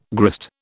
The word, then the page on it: grist: British English